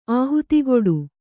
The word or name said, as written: ಆಹುತಿಕೊಡು